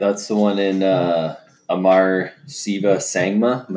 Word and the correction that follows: Sangam: Am I saying that right? – Sangam, yeah. – Sangman? – Sangam. It's S-A-N-G-A-M, I think.